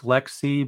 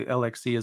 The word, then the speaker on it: LXC: It's pronounced Lexi, but I might say LXC as well out of habit, because before I heard it spoken, I've always said it that way.